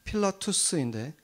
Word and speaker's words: 필라투스: 빌라도가 이제 그 로마식 발음으로는 필라투스인데 그 필라투스라는 그 돌 비문이 발견이 된 거죠.